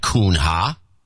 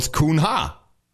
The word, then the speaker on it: Cunha: It's not Kuhn-ha. – It's Kuhn-ha. – Livia Aguiar Kuhn-ha. – Livia. – Anyways, in the Amazon, apparently. In Brazil. So that's a Portuguese name, so it's pronounced differently.